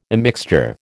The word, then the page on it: immixture: American English